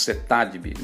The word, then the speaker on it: CETADEB: Nós falamos CETADB, mas se escreve CETADEB.